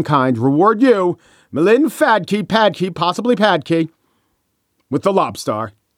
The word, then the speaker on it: Phadke: It was from, hope I'm pronouncing this right, Melinda Padke, P-H-A-D-K-E. Usually people don't go with the F pronunciation in surnames, but if you do, I'm sorry, Melinda Padke. I'm going to say Padke.